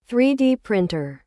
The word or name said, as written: 3D printer